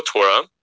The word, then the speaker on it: Torah: And hinting at the two Torahs are written in Oral Torah, but it's pronounced Torah. If you just read it, you know, it's read uh, is read aloud as Torah in the singular.